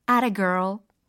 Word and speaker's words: atta girl: atta girl, atta boy, a t t a g i r l, a t a girl. 예. That's a girl. 이 얘기가 좀 축약된 발음 버전이라고 생각하시면 이해하기 쉬우실 것 같아요.